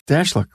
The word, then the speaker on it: Stashluk: Stashluk.